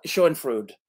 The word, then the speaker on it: Schadenfreude: Schadenfreude. – Schadenfreude. Okay. Yes. Schadenfreude. Okay. – Schadenfreude. That's how it's pronounced.